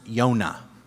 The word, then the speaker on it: Yonah: Jonah in Hebrew is pronounced Yonah.